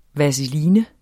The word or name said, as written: VASELINER